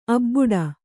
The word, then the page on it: ಅಬಾಡೆ: abāḍe